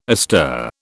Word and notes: astir: British English

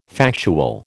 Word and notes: factual: American English

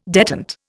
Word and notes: detent: Indian

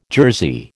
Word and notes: jersey: American English

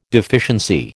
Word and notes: deficiency: American English

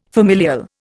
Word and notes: familial: Indian

